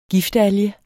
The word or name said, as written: giftalge